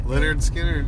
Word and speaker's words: Lynyrd Skynyrd: So we're on our way to Bangor, and we're going to a Lynyrd Skynyrd concert. Is that how you say it? – Lynyrd Skynyrd, yes.